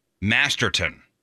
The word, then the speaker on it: Masterton: Bill Masterson. I'm saying it wrong. The Bill Masterton Memorial Trophy, presented by the Professional Hockey Writers Association to an NHL player who shows perseverance, sportsmanship, and dedication to the game.